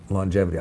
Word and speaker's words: longevity: and I think what he calls indefinite longevity. I love that he says it with the hard G also.